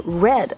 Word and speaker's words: read: Uh, 哎，其实那个 read 呢，我们应该读成是 read 啊，过去式了。